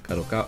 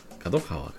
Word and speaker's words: Kadokawa: La siguiente información es bastante interesante y resulta que Kadokawa Corporation, Kadokawa, Kadokawa, creo que es la pronunciación, Corporation, ha formado una alianza capital, capital con Agent y Sony para fortalecer sus negocios de juegos y animación.